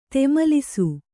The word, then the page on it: ಧೂಮಲಿಸು: dhūmalisu; kannada